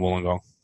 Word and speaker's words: Wollongong: Have I said that right, or is it sort of halfway between Sydney and Wollongong? – Mate, you're perfect. Wollongong's fine.